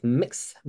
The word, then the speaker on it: Mx: So MX is like a like the gender neutral version of Mr. or Miss or Mrs. Most people pronounce it like mix or mixta.